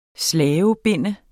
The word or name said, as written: slavebinde